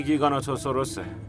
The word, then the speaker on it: گیگانوتوسوروس: اون یه گیگانوتوسوروسه.